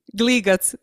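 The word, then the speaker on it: Gligac: Her last name is G, spelled G-L-I-G-I-C, but on Instagram it's G-L-I-G-A-C. – Yeah, that is my nickname. – Oh, that's your nickname. How do you say that? – Gligats. That's how my friends call me back home.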